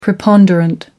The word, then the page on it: preponderant: American English